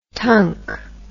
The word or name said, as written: tunk